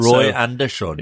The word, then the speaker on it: Roy Andersson: Roy Andersson.